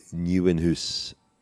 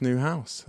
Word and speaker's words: Nieuwenhuis: Joris Nieuwenhuis. We need Orla to help us with the pronunciation. – Joris Newhouse, I think. I think that's more or less the translation.